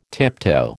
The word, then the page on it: tiptoe: American English